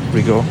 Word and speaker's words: Rigaud: His name is spelled R-I-G-A-U-D, however you say that in French. – Rigo, yeah.